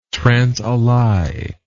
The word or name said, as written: transalai